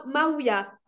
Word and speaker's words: Mavia: Ah, Maouia, M-A-V-I-A.